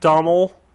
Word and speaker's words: Domhnall: Domhnall. Okay. – It's supposed to be like the weird Scottish version of Donald.